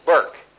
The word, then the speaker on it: Bourke: Burke. B, how do you spell it again? B-O-U-R-K-E. Burke.